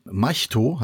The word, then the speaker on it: Mahito: Es geht um den Jungen, Maito heißt er. Also das wird Mahito geschrieben, aber die haben es einmal Maito ausgesprochen. Ich glaube, das ist dann auch wahrscheinlich korrekt.